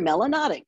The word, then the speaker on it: melanotic: If you have a lot of melanin in your skin, you're melanotic, which rhymes with exotic.